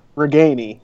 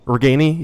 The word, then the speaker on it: Ragaini: um, I wanted to ask you guys about Nico Ragaini. Is that how you say it? Right. – Ragaini. – Ragaini. Yeah.